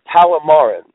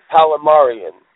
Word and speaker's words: Palamaran: And I should say it's pronounced Palomar or Palomarin. So Palomarin Empire, not Palomarian.